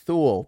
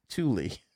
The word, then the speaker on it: Thule: Thule, pronounced Thule.